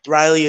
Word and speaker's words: Riely: Riley is spelled R-I-E-L-Y.